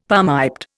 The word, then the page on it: palmiped: Indian